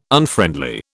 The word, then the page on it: Unfriendly: British English